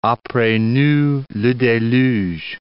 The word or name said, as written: apres nous le deluge